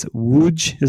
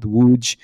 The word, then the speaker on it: Łódź: Poland just got a dedicated facility in, I'm going to mispronounce it, but I think it's Łódź is the pronunciation. It looks like it's L-O-D-S-Z in English, but I've been told on kind of reliable authority it's called Łódź. And so, but they've only had it for a year.